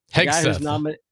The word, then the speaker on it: Hegseth: Hegseth.